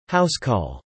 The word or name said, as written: Housecall